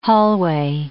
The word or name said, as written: hallway